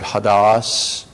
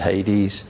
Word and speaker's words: Hades: That in the Greek is the word Hadas, or maybe we pronounce it sometimes as Hades.